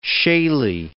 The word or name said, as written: shaley